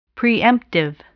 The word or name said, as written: preemptive